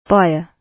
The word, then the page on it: buyer: British English